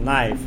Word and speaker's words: knife: Да, я знаю, что кей не произносится, найф.